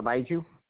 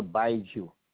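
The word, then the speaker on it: Byjoo: Bupal Baiju. – I'm sorry? – Bupal Baiju.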